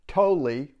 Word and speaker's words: Tolle: Tolle, which I learned that's how you say his name, talks about suffering unconsciously, or sorry about that, suffering consciously.